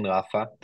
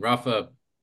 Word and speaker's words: Rafah: Yeah. Rafah, I'm saying it wrong. Rafah, it's the furthest south, correct? – Yeah.